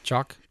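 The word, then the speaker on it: Chalke: Chalk. – Yeah. – With a silent E. – Shh. The E is silent.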